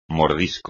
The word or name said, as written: mordisco